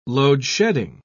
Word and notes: load-shedding: British English

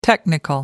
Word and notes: technical: American English

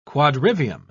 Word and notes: quadrivium: British English

